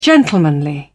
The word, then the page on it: gentlemanly: British English